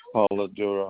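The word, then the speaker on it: Polidura: Yeah, 6202 Robert Paladura.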